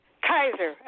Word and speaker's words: Kaiser: Kaiser, as in Kaiser Aluminum, Kaiser.